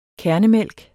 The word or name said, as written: kærnemælk